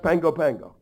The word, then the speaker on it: Pago Pago: The island is P-A-G-O-P-A-G-O. You think it's pronounced Pago Pago. It's actually pronounced Pango Pango.